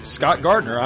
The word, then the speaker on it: Scott Gardner: And hey, you can friend me, Scott Gardner, on Facebook, too. My name is spelled S-C-O-T-T-G-A-R-D-N-E-R.